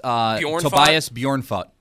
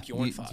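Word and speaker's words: Tobias Björnfot: It is Tobias Bjornfot. – Oh, I did pretty well there. – Yeah, Bjornfot.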